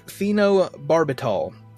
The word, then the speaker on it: phenobarbital: and each took, now here we go with the names, each took phenobarbital. It's a sedative that was prescribed to Emil to calm their nerves. – That is actually how you say it.